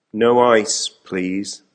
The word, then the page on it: no ice please: American English